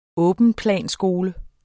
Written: åbenplanskole

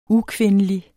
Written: ukvindelig

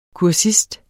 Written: kursist

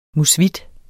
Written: Musvitten

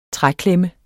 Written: træklemme

